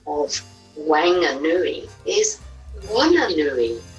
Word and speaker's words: Whanganui: Whanganui, the WH is pronounced like F by the locals. Whanganui.